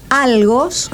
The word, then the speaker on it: algos: Algos, con acento en la A, dolor.